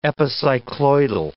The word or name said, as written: epicycloidal